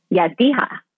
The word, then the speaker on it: Yazdiha: Yazdiha.